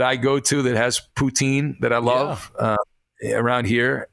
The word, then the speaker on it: poutine: He says it's not pronounced poutine, but rather poutine-zin or something like that. Poutsinn, because it's French Quebec. It is pronounced poutine.